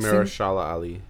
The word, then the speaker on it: Mahershala Ali: Mercer. – It's a yes for me. – I am sorry I couldn't pronounce his name. My apologies. The next Mahershala Ali.